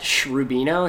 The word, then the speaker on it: Shrubino: My Twitter is at Shrubino. It's S-H-R-U-B-I-N-O.